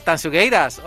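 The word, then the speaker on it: Tanxugueiras: Y tengo la enorme fortuna de poder hablar ahora mismo con Sabela, una de las integrantes de Tansugueiras.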